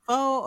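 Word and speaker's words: phở: Is it pho or pho? Like, I've been wondering. – It's pronounced pho, but, you know, we be like, right. We read it as pho.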